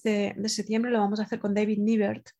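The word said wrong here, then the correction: Nibert: David Nybert, que se pronuncia realmente.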